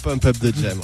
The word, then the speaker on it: Pump Up the Jam: Ok, alors ça, c'est Pump Up de Jam. – Pump Up the Jam, ouais.